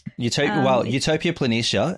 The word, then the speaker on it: Utopia Planitia: The orbiter will release their rover then in April 2021 in a large basin called Utopia Planitia. I think that's how you pronounce it. – Utopia Planitia,